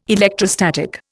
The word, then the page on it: electrostatic: Indian